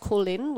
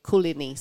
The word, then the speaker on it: Koorliny: We have a website, which is it's pronounced Kulin, which is a Noongar word meaning come in, go in, walk in. So it's that kind of flow of people in and out of the art centre, but it's spelt. Kulini, so K-O-O-R-L-I-N-Y.